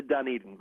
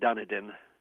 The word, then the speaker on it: Dunedin: I wanted to give you points for pronouncing it correctly before with your Blue Jays. – Yes. – For some reason, down here, they often call it Dunedin.